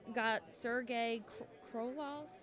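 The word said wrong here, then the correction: Korolev: How do you pronounce his name? – Korolov.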